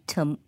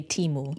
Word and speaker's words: Temu: And I'm wondering whether the arrival of the likes of Shein and PDD, Temu. Well, Americans say Temu.